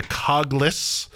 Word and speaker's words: Cogless: We learn that there's such a thing as, they call them, I need to be very careful in my pronunciation, the Cogless, C-O-G-L-E-S-S, the Cogless.